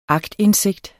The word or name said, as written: aktindsigt